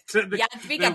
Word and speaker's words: Jadwiga: And that it's, I mean, the Hedwig, Jadwiga, is that how you say it? – Jadwiga, very good.